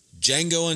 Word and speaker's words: Django: Django Unchained. Django is spelled D-J-A-N-G-O. Django. D-J-A-N-G-O.